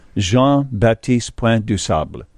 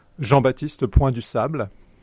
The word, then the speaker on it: Jean-Baptiste Point du Sable: Jean-Baptiste Point du Sable. Did I say that correctly? – Yeah, your pronunciation is quite good.